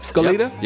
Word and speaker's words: Goleta: Near the Santa Barbara area in Goleta, is that how you spell it? – It is. – Say it? – Yep. – Goleta, yep.